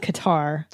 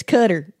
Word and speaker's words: Qatar: When you're in Qatar, they go, it's Cutter. I'm pretty sure it's not pronounced Cutter. – Well, the journalist kept saying Cutter. – Qatar. I think it's Qatar. Like that, like Qatar, but not Cutter.